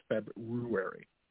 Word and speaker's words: February: See, I'm like everybody else where I just want to call it February, but it's February. That is the correct pronunciation.